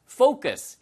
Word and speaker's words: focus: 이거 for u cos예요. 이게 focus 이렇게 발음하셔야지 발음을 좀 잘못하시면은 약간 이상한 소리가 됩니다. 그래서 focus, focus 아시겠죠?